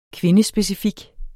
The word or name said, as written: kvindespecifik